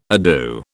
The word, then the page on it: ado: British English